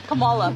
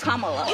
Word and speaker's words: Kamala: "Earlier you said Kamala. The way she pronounces her name is Kamala.